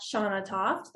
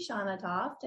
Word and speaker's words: Shanna Toft: You can check me out on Instagram at Shanna Toft. That is S-H-A-N-N-A-T-O-F-T.